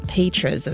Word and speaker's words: Petras: I mispronounced that earlier, but Petras is, I think, how you say it, with a Z at the end there, or a Z sound.